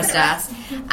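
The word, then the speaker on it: Kérastase: I can't pronounce Kerastase. Yeah. Kerastase.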